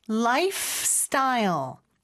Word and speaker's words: lifestyle: 그 다음에 life 스타일이 아니고 lifestyle. F에서 S로 넘어가는 거. fs. 요거 많이 연습해 주시면 좋을 것 같아요.